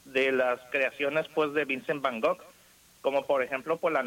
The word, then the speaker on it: Van Gogh: Vincent Van Gogh, Vincent Van Gogh. – Yo quiero oír, David, quiero oír, ¿eh? Sí, Van Gogh es como se pronuncia realmente el apellido, pero bueno.